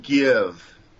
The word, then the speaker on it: Kyiv: Do the correct pronunciation of Kiev is Kyiv, I guess, in Russian or in Ukraine. What is the preferred pronunciation? – Well, everybody here calls it Kiev.